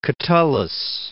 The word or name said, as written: Catullus